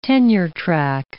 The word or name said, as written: tenure-track